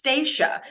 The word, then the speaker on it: Stasha: It's like my name is actually pronounced Stasha. It's spelled like Stasha.